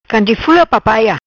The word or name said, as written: Canje Fula Papaia